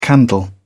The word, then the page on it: candle: British English